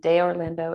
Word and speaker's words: De Orlando: So if you Google Sarah De Orlando, and De Orlando is spelled D-E, and then Orlando is just like the city.